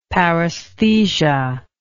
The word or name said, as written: paraesthesia